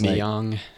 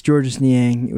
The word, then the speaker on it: Georges Niang: Or I can't remember how to say his name, but they didn't pronounce it right. And I was Niang. – I was like, it's Georges Niang.